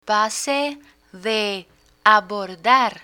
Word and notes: pase de abordar: Spanish

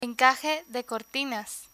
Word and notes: encaje de cortinas: Spanish

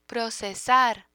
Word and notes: procesar: Spanish

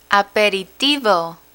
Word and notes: aperitivo: spanish

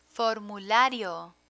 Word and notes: formulario: Spanish

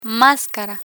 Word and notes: mascara: spanish

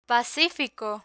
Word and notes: pacifico: Spanish

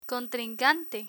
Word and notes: contrincante: Spanish

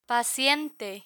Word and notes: paciente: Spanish

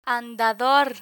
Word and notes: andador: spanish

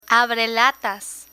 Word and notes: abrelatas: Spanish

